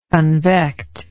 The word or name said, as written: convect